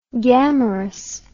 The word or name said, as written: gammarus